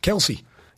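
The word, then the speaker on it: Kelce: Jason's brother Travis went on a podcast and said the actual pronunciation was Kels, which shocked some of his Chiefs teammates.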